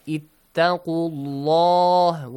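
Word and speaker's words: اتقوا الله: If we have a fatha or a dhamma sound before the word Allah, the letter lam must be pronounced heavily, like ittaqullah with full mouth.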